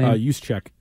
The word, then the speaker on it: Juszczyk: Juszczyk.